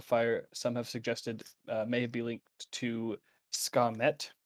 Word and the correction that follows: Sekhmet: I think that's how you pronounce it, due to her lioness Leonin characteristics and her role in protecting the Lake of Fire. So, Skamet might be... – Sekhmet? – Maybe. I don't know. S-E-K-H-M-E-T.